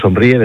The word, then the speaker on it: Somríe: Pero el grupo Somríe, tal y como suena, Somríe, de sonreír, pero con M.